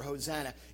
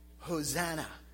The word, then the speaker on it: Hosanna: The Greek word for Hosanna, it's pronounced this, Hosanna.